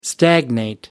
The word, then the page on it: stagnate: American English